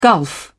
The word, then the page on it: gulf: British English